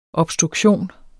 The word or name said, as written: obstruktion